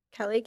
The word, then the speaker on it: Kelly: It's Kelly, K-E-L-L-Y.